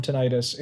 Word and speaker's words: tinnitus: Martin suffers from tinnitus. Is that how you pronounce it? – Tinnitus. – Yeah. Tinnitus. Thank you.